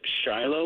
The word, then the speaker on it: Shiloh: You said Shiloh Water? – Shiloh Water. Yeah, they're S-H-I-L-O, I believe, is how they're spelled. And so L-O-H. – L-O-H. Thank you. I was forgetting that silent H.